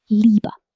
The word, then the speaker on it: Liebe: When you see IE in German, it makes a long E sound, like the word Liebe, meaning love. Think of it this way. The I is telling the E to be long. So IE equals E.